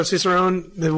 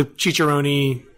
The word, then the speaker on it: Cicerone: So Cicerone, which you actually did a better job pronouncing it for the first time than most people. The Cicerone...